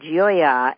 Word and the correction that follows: Gioia: and you said, no, it's Gioia. Your last name, which is spelled G-I-O-I-A, is the word for joy.